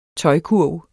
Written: tøjkurv

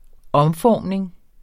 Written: omformning

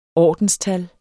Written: ordenstal

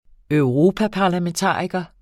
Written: europaparlamentariker

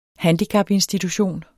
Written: handicapinstitution

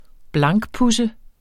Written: blankpudse

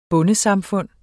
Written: bondesamfund

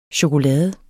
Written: chokolade

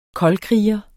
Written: koldkriger

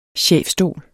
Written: chefstol

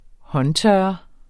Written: håndtørrer

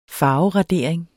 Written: farveradering

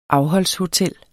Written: afholdshotel

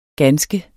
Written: ganske

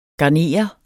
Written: garnere

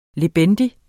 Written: lebendig

